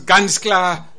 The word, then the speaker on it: ganz klar: I'm so happy that you pronounce ganz klar the way the people do it in Niedersachsen in Germany.